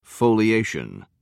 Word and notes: foliated: American English

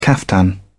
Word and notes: caftan: British English

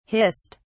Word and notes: hissed: British English

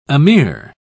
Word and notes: ameer: British English

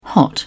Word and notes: hot: British English

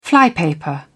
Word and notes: flypaper: British English

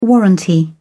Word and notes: warranty: British English

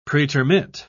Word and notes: pretermit: British English

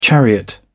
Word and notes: chariot: British English

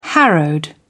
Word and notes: harrowed: British English

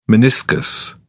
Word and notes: meniscus: British English

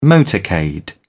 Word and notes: motorcade: British English

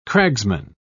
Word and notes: cragsman: British English

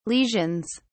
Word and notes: lesions: British English

